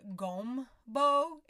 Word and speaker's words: gombo: And gumbo actually comes from the word, I guess you would pronounce it gombo. It's spelled G-O-M-B-O.